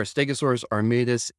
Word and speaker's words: Stegosaurus armatus: specifically the Stegosaurus armatus. Am I saying that right? – You are. Stegosaurus armatus is the first named stegosaur.